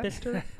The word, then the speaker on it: Bicester: Bicester? – You got it.